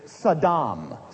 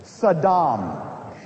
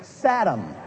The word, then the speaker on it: Saddam: The proper pronunciation is Saddam. Say it with me, will you please? Accent on the second syllable. Saddam. Our president, for whatever reason, insists upon calling him Saddam.